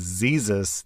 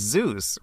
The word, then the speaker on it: Zeus: However, I was nervous, and for some reason, I referred to him as Zeezus, that's Jesus with a Z. The presentation was like 8 minutes long, and I said it wrong countless times. It wasn't until I finished the presentation that a kid asked, you know it's pronounced Zeus, right?